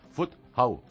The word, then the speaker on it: Fuzhou: Fuzhou.